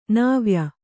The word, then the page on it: ನವ್ಯ: Kannada